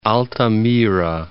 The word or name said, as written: Altamira